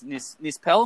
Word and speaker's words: Nispel: Okay, so the director, Marcus Nispel, am I pronouncing that correctly? – Yes, he's also directed the Texas Chainsaw remake, yep.